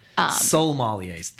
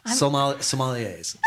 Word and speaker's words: Sommeliers: So, well, that the Court of Master Sommeliers is an organization that Sommeliers. That's what it is. There's an L in there when she's pronouncing it. Sommeliers.